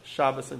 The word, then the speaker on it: Shabbat: Shabbos and Shabbat are just different pronunciations, right? The Hebrew, the Sephardic pronunciation is Shabbat, Ashkenazic is Shabbos.